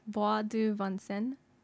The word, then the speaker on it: Bois de Vincennes: I think it's more like Bois du Vincennes.